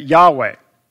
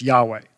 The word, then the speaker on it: Yahweh: But if you add in the vowels, it probably would have been sounded and it's been spelled like this, Yahweh.